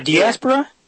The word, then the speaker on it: diaspora: Diaspora. I've heard it pronounced. – Is it diaspora? Okay. – Yeah, diaspora.